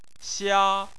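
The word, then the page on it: xia: tone 1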